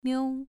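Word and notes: miu: tone 2

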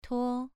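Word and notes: tuo: tone 2

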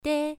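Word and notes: dei: tone 1

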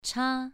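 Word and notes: cha: tone 2

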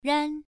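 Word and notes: ran: tone 1

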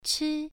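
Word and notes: chi: tone 1

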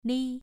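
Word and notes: li: tone 2

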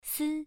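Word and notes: si: tone 1

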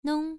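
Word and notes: nong: tone 1

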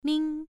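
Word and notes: ming: tone 1

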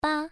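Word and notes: ba: tone 1

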